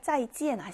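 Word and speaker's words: 짜이찌엔 (再见): 근데 예외 발음에서 에이 발음은 에 발음이 납니다. 그래서 이엔이라고 읽어주시면 맞겠죠. 이엔이라고 읽어요. 우리 헤어질 때 하는 인사말 짜이찌엔 아시죠? 그 짜이찌엔의 찌엔이 바로 이렇게 이엔 하고 쓰입니다. 그래서 이엔 발음이 난다.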